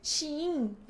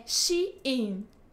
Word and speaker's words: Shein: Shein. É porque eles mandaram e-mail esses dias falando que pronunciar o nosso nome correto. É Shein.